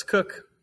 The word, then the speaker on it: Koch: Did I say that right? – It's Cook.